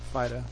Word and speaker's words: Phydeaux: It's like the joke about naming your dog P-H-Y-D-E-A-U-X. – Fido. – Fido. – Yeah. Mm-hmm. And that G-H-O-T-I, your fish.